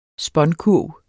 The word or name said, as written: spånkurv